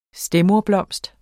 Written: stedmoderblomst

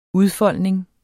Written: udfoldning

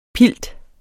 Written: pilt

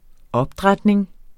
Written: opdrætning

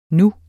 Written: nu

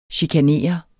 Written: chikanere

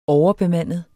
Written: overbemandet

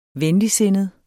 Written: venligsindet

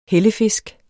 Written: hellefisk

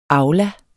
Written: AULAS

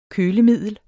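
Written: Kølemiddel

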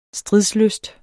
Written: STRIDSLYST

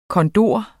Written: kondor

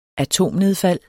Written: atomnedfald

